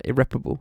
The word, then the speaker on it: irreparable: Irreparable. I say the second. I say the first one. Irreparable. Irreparable, irreparable. I don't know who says that. With a stressed syllable sounding like pear, irreparable, irreparable.